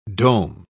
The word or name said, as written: dome